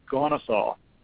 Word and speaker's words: Kennesaw: If you spell it, it looks like Kennesaw, but as I looked up the proper pronunciation, it's Kennesaw.